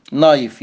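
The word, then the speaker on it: knife: اي كلمه تبدا بكي ان ما ننطقش الكي، زي نو يعني يعرف، نوليدج معرفه، نايف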